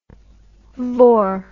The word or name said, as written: vore